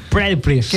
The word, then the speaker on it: Pretty Please: Amante mía, esta canción que se llama Pretty Please. ¿Es correcto? – Sí. – Pretty Please. – Qué gran pronunciación, amigo.